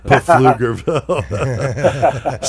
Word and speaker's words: Pflugerville: Pflugerville. Silent P.